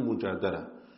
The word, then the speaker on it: Al-Mujadila: and that is Surah Al-Mujadila or Mujadala. Both are correct pronunciations, Mujadila and Mujadala.